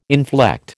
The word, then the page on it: inflect: American English